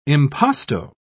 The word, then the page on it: impasto: British English